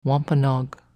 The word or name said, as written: Wampanoag